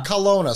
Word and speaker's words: Kelowna: Kelowna. Is that how you pronounce that? – Kelowna.